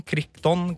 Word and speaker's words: Crichton: som er laga av Michael Christian eh, Christon, Crichton, Crichton, unnskyld uttale, som også laga eh, boka bak Jurassic Park.